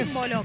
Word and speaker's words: Rúmbolo: Así se pronuncia, no tiene acento, ¿no? Rúmbolo. – Así Rúmbolo, es. Con acento en la U.